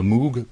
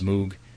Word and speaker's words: Moog: How to pronounce Moog? I know it's really pronounced Moog, but I can't do it. Moog is what I'm going to say from now on.